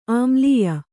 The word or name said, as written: ಅಮೌಲ್ಯ